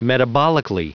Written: metabolically

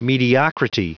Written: mediocrity

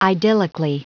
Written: idyllically

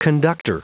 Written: conductor

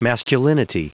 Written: masculinity

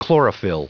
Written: chlorophyll

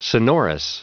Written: sonorous

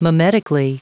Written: mimetically

